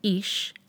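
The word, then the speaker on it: Ish (אִישׁ): In the Genesis narrative, chapter 2, verses 20 to 25, not only do man, Ish, and woman, Isha, phonetically sound like a pair, but Isha also means her man in Hebrew, because out of her man, this one was taken.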